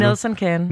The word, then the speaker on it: Nelson Can: Nelson Kahn... Can, var det rigtigt udtalt? Nelson Can.